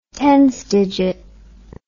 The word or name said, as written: tens digit